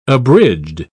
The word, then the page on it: abridged: British English